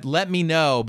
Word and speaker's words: Lemino: Let me know, but it's spelled Lemino.